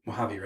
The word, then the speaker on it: Mojave: How do you say it? Mojave or Mojave? Mojave, right? – Mojave. – Yeah, Mojave. I always get that wrong.